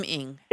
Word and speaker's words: Ng: I believe it's Kim Ng. – Okay. Kim Ng.